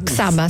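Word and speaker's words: Csaba: Giusto la pronuncia? – Saba, sì, come Saba. Se fosse scritto con la X, okay, facile.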